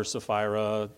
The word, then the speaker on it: Sapphira: Now, I know most people call her Sapphira. You know me, I like Greek. Her name is Sapphira. That first P is pronounced, but you can call her Sapphira if you want to.